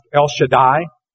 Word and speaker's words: El Shaddai: It's a compound name pronounced El Shaddai.